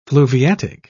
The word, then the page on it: fluviatic: British English